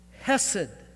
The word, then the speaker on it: hesed: The word is hesed. Hesed. H-E-S-E-D with the accent on the first E. Hesed.